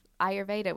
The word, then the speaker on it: Ayurveda: You must know my friend Jasmine Hemsley, the queen of Ayurveda, which I can never say that word.